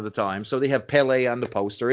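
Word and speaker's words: Pelé: Oh, it's definitely Pele.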